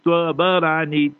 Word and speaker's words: Tabarani: And in Tabarani, people pronounce the name wrong. It's Tabarani, T-A-B-A-R-A-N-I.